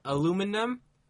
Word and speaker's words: aluminum: Aluminum is the right way to say it. I'm trying to say it the wrong way. Aluminum. They'll switch the M and the N.